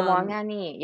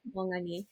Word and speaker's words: Wang Anyi: And that is The Song of Everlasting Sorrow by Wang Anyi. I'm not sure if I'm saying her name correctly. – Wang Ani. Yeah, pretty close. – Okay, Wang Anyi.